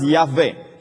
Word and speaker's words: Yahweh: So it's pronounced Yahweh. Yahweh is the covenant-keeping name of God.